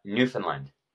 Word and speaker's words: Newfoundland: St. John's, Newfoundland is... – What did I say? Newfoundland? – Yeah, you did. – Because that's how it's spelled. What is it? Newfoundland? – Newfoundland.